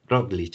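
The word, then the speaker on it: Roglič: Con el Roglic este, o cómo se dice, que sí, bueno, pues Roglic, sí.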